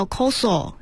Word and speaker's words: 酵素: 嗯，它的那个日语发音叫 koso，是其实就是酶啊。